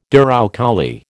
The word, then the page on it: douroucouli: American English